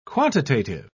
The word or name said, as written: quantitative